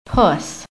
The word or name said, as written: PUSS